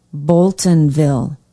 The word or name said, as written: boltonville